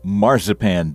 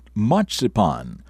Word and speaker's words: marzipan: It's Wednesday, the 12th day of January, and it is National Marzipan Day. Some people pronounce it marzipan, while others call it matzipan.